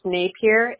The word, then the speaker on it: Napier: And then is it S-O-N-Y-A? – It's J-A. – J-A. And, Sonia, what's your last name? – It's Napier, N-A-P-I-E-R.